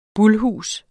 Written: bulhus

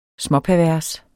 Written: småpervers

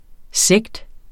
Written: sekt

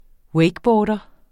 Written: wakeboarder

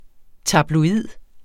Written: tabloid

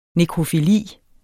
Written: nekrofili

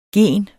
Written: gen

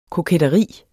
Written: KOKETTERIET

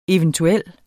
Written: eventuel

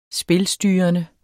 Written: spilstyrende

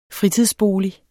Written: fritidsbolig

